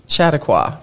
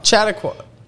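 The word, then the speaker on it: Chautauqua: The resort, which was partially based on the Chattanooga Institute in New York, was envisioned as a Just pretend we said it right. – I think it's Chattaqua. Chattaqua.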